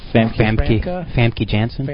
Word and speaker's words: Famke Janssen: Famke Janssen. – Famke Janssen. That's how she told me to say it.